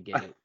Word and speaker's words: gi: Gi, what's it called? Is it a gi? – It's spelled G-I, but it's pronounced gi.